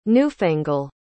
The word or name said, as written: newfangle